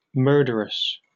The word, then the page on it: murderous: British English